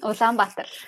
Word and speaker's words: Ulaanbaatar: But most of the people are concentrated in the capital city, which I hope I will pronounce correctly, Ulaanbaatar? – Ulaanbaatar, yes.